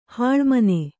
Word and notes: ಹೊಲೆಮನೆ: hole mane

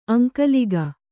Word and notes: ಅಂಕಲಿಗೆ: Kannada

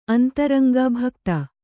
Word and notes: ಅಂತರಂಗೆ: antaraŋge

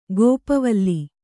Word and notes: ಗೋಪವಲ್ಲಿ: kannada; gōpa valli

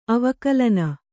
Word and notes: ಅವಕಲನ: avakalana

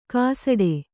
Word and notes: ಕಾಸಡೆ: kāsaḍe

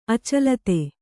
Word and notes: ಅಚಲಿತೆ: kannada

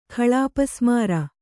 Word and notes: ಖಳಾಪಸ್ಮಾರ: khaḷāpasmāra

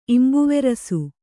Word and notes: ಇಂಬುವೆರಸು: Kannada

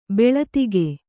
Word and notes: ಭಲ್ಲಾತಕಿ: kannada

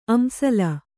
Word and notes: ಅಂಸಳ: Kannada

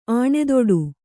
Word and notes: ಆಣೆದೊಡು: Kannada

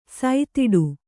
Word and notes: ಸುತ್ತಾಡು: suttāḍu

